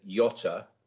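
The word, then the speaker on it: Göta: But yes, so the Swedes refer to this as the Gota Canal. I think the G is pronounced like a Y, but I'm going to say the Gota Canal just because it makes it easier.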